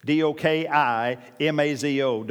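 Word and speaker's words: Dokimazo (δοκιμάζω): Now, anytime you see A A Z in Greek, it's generally got a D pronunciation in it. So it's D O K I M A Z O. Dokimazo, but you pronounce it dokimazo.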